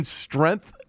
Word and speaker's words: strength: Isn't the G in strength a silent G? – Yeah, there's no G. It's not squank. There's no G there.